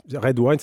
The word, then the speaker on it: Raid: Alors, Red Wine, ça s'écrit R-A-I-D, évidemment.